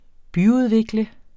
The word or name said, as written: byudvikle